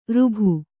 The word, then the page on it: ಋಭು: Kannada